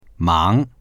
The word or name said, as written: mang2